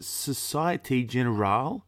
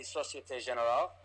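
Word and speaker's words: Société Générale: Is it Societe Generale? – Yes, that's it. – You're doing that through the World Cup? – So they say Societe Generale.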